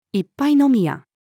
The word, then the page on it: 一杯飲み屋: female